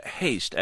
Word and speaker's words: haste: And haste, as in haste makes waste. – Hast makes wast. – Hast so, makes wast. – Yeah, so hast rhymes with tast, and there's also another one, which is the word feast that comes up a lot in Shakespeare.